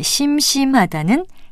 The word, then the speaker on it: 심심하다: 그리고 참고로 우리 고유어의 심심하다와는 달리 한자어인 심심하다는 첫 음절을 길게 발음합니다. 발음말 고운말 아나운서 변희영이었습니다.